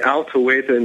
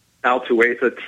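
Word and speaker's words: Al-Tuwaitha: You've heard of, during all the radiation and everything, you've heard about Al-Tawaita nuclear research facility, have you not, in Iraq? – Say that again? – It's Al-Tawaita nuclear research facility.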